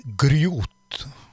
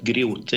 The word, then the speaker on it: Grjot: Uttaler det rett? Grjot? – Ja, jeg syns det er helt supert. Grjot. Jeg kan ikke uttale det bedre. Så det, det skrives jo G-R-J-O-T, så det er jo Nettopp.